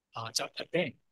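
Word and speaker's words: Aben (אבן): 石头在希伯来文里面叫 Aben。这个词和它另一个词很像，是儿子。儿子和石头是很类似的，他们的母音 consonant 是一样的，只是发音不一样。儿子叫 Ben，石头是 Aben 阿本，所以你发现发音是很类似的。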